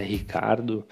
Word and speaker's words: Ricciardo: O Ricciardo vem da Itália, apesar que a pronúncia correta, é o que ele, pelo menos é o que ele pede, né? Por ter nascido na Austrália, a pronúncia é Ricardo.